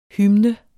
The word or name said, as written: HYMNER